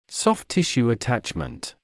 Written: soft tissue attachment

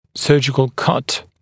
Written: surgical cut

